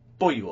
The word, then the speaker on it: Beaulieu: Beaulieu, okay.